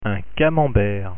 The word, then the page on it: camembert: French